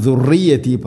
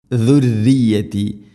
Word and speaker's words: ذريتي: ومن ذريتي, ഇനി ذريتي റാഇന് ശദ്ദുണ്ട്, എന്നാലും ദുർറിയത്തി പറയരുത്, തർക്കീഖ് ചെയ്യണം, നേർപ്പിക്കണം. ദുർറിയത്തി, ദാലിൻ്റെ ഉച്ചാരണം ശ്രദ്ധിക്കുക, ദുർറിയത്തല്ല, ദുർറിയത്തി.